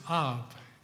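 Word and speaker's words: Av (אב): The Hebrew word for father is Av, and it's spelled A-V.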